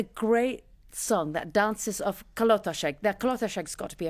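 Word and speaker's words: Kalotaszeg: Kolotaseg? Almost right. Kolotaseg, as you pronounce. You always pronounce Kolotaseg.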